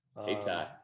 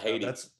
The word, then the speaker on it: Hayti: Hayti is another one in class one. – Hayti. Not Haiti.